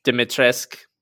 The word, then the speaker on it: Dimitrescu: Dimitrescu.